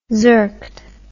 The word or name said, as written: zerked